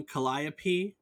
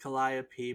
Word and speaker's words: calliope: The Greek muse by the same name is produced calliope, but the instrument was usually pronounced calliope.